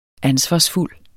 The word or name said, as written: ansvarsfuld